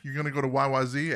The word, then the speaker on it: YYZ: And then they said, well, let's throw in the Morse code, you know, rhythm by the way, they pronounce it YYZ. – YYZ, that's correct. That's what The radio, Z sounds too much like other letters, so they have to change the pronunciation so nobody mistakes it. – No, all Canadians said when they say Z, there's no Z in Canada. It's Z. That's how Canadians say Z.